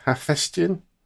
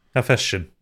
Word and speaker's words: Hephaestion: I don't know how to pronounce this. I'm going to go with Hephaestion. – Hephaestion. – You got it bang on, mate.